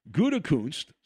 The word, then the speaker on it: Gutekunst: Now, the Packers general manager, who's got a very tough name to pronounce, Mike Gutekunst, say that properly. Make sure you don't say it wrong. Mike Gutekunst has shown to be insincere and a bit disingenuous with his public comments.